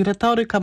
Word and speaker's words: retoryka: Z uporem maniaka akcentuje na o, a nie retoryka. Na przedostatnią. – No dobrze, na trzecią od końca. Na tak jak pan redaktor, bardzo dobrze pan redaktor akceptuje retorykę. – Czyli słusznie i tu nie ma żadnej wątpliwości. Tak, te ika pochodzenia łacińskiego lub greckiego akcentujemy na trzecią od końca, czyli retoryka, matematyka.